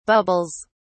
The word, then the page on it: bubbles: British English